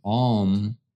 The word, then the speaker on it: Aum: Now we're at our third eye, and the sound is Aum. A-U-M is the sound. Of course, you know Aum, like O-M. It's kind of pronounced like A-U-M in this case, from what I hear, from what I've done in the past.